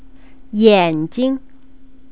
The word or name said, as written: yanjing